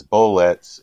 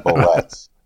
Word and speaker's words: bullets: He gets the wrong syllable on rhyming bullets. He says bullets instead of saying bullets. – Yeah. – He's rhyming it with ets.